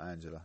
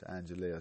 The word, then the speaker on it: Angela: It's not Angela. It's Angelia. It's pronounced. It's spelled Angela. It's pronounced Angelia.